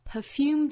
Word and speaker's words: perfume: It's a voyage too long, perfume too strong. Perfume 这个词它的重音位置有两种可能，我们可以把它读成 da da 的节奏模式，也可以把它读成 da da. Perfume, 两种读法都对。